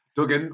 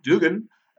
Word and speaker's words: Duggan: It was Duggan, or Dugan, as it was often pronounced on commentary by Bill Watts, up against them, former member of the Rat Pack alongside him, Ted DiBiase, the Million Dollar Man.